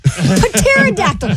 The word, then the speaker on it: pterodactyl: Because the P is silent. Pterodactyl.